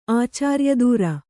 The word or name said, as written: ಆಚಾರ್ಯದೂರ